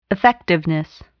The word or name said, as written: effectiveness